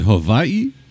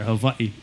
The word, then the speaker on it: Hawaii: So is it Hawaii? – Like, yeah, that's what they say out there. Hawaii with a V. – Yeah, that's how they pronounce it.